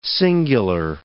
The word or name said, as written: singular